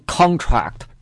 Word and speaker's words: contract: 做名词的时候它的意思是合同，读成 contract，重音在前面。如果做动词呢，这个单词它的意思是收缩，比如说这个金属遇冷它也会收缩，重音在后面，读成 contract，重音在这里面啊，重音在后这里面。